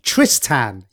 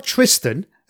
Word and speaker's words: Tristan: His name was Tristan. Tristan. Yep, I've said that right. Not Tristan, as we'd say in the UK.